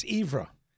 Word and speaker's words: Evera: Thanks to um, Stephen Van Evera. I Hope I'm saying that right. Is it Evra? It's good enough. – It's Evra.